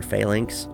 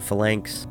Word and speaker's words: phalanx: I've always pronounced it phalanx, but I recognize that my small town Nebraska pronunciation is probably not accurate, and I see a lot of people online calling it phalanx, so that's probably how it's actually pronounced.